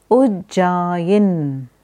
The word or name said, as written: Ujjayi